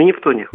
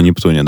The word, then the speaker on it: Нептуне: потому что на Нептуне, или на Нептуне, да, правильно говорить? – На Нептуне. – На Нептуне, да.